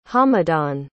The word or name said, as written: hamadan